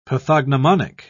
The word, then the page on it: pathognomonic: British English